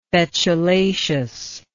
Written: betulaceous

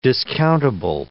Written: discountable

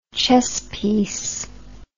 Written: chess piece

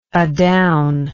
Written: adown

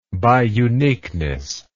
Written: biuniqueness

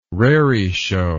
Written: raree show

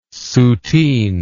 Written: Soutine

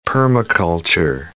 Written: permaculture

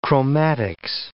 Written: chromatics

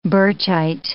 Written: Birchite

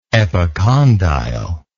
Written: epicondyle

